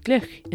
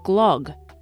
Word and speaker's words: glögg: It's called Glühwein in Germany or Glögg in Sweden, which I always pronounced Glog because that's how it's spelled.